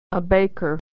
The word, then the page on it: baker: American English